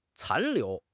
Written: 残留

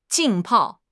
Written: 浸泡

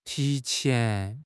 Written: ti2 qian2

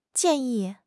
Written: jian4 yi4

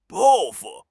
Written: 抱负

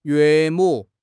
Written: 岳母

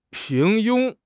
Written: 平庸

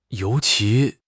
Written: you2 qi2

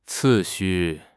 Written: 次序

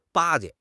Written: 巴结

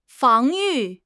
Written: 防御

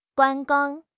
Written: 观光

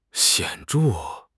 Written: 显著

